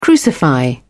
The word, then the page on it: crucify: British English